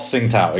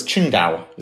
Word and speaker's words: Qingdao: It's not Tsingtao, exactly. And they've done a lot of marketing campaigns to let you know. No, it's Qingdao is how you pronounce it.